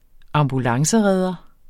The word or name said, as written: ambulanceredder